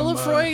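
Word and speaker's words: Laphroaig: Is it Lafroy? Is that how you pronounce it? I don't even know how you pronounce it. – It is.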